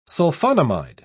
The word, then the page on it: sulfonamide: British English